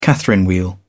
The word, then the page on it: catherine wheel: British English